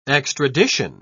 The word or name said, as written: extradition